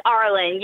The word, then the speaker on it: Arlen: So is it Arlen? Do we say your name Arlen? Is that how you pronounce your last name? So we say it right? – It's Arlen. Yeah.